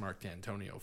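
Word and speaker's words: Mark Dantonio: I know they beat us this year, but you know, I mean, not that I'm the biggest Mark D'Antonio fan.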